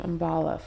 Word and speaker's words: MBalla: So at first name MBalla. First name MBalla on Twitter and Instagram.